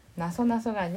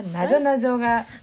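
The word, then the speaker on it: なぞなぞ: 나소나소가 아니라 나조나조가. – 나리가 나소나소. – 한국말로 수수께끼입니다. 이 책은 뭐냐? 엄마가. – 헌가 이마 거기에 아리마스. – 한국 책이야. – 이거 한국의 헌데. 이거 한국의 나조. 한국어로 써진 한국의 나조나조가.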